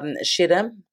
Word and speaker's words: Schiedam: Now, can you explain the seal of, and I'm probably going to get the pronunciation of this wrong, but Schiedam? – No, very good.